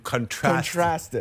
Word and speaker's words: contrast: But when you contrast it, I don't know why I said contrast like that. – When you contrast it. – Yes, I understand. – Emphasis on the wrong syllable.